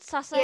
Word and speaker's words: sasaeng: Sasaeng. – Yeah, sasaeng. – Okay. Sasaeng. Okay, sasaeng. – It's uh, it's spelled S A S A E N G.